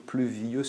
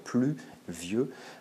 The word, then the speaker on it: pluvieux: On peut remarquer que cette comparaison, eh bien, elle est déjà ambiguë au premier vers, puisqu'il s'agit d'un pays pluvieux, avec cette insistance hein, sur pluvieux, sur la diérèse, qu'il faut faire pour avoir l'alexandrin, les 12 syllabes, qui connote donc hein, la tristesse et la mélancolie, et qui aussi connote la vieillesse, puisque la rime au vers suivant nous autorise aussi à le prononcer en 2 syllabes, pluvieux.